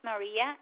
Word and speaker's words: Maria: My first name is Maria, M-A-R-I-A. – That was M-A-R-I-A. Will you spell that last name? – Marin, M-A-R-I-N.